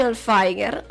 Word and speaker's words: Hilfiger: ha mantenuto il suono duro della lettera G, che si pronuncia come se fosse seguita dalla lettera H, Hilfiger.